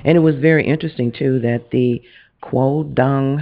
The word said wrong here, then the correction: Taekwondo: Taekwondo, yeah.